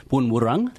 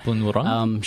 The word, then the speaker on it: Boon Wurrung: So Boon Wurrung, that's how you pronounce it. – Boon Wurrung.